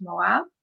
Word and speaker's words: Moab: Is it pronounced Moab? – Yes.